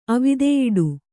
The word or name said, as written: ಅವಿದೆಯಿಡು